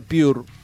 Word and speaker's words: PBRS: PBRS se pronuncia Pure o Pure. En belga será PBRS o Pure, formado en 2023, está conformado con un dúo dinámico que proviene del corazón de Bruselas.